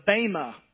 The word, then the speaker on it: Bema: I said it to Maria like, I don't know, 2 minutes ago, and she corrected me. So I probably said it wrong again. But it's BEMA, the Bema Podcast.